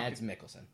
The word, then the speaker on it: Mads Mikkelsen: They ruin, they loot, they like Mads Milks How do I say his name? Mads Mikkelsen.